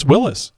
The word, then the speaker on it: Willys: I think it's Willys. So I bet you I've been saying that wrong. – It is Willys.